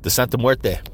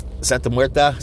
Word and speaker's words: Santa Muerte: The Santa Muerte. Santa Muerta.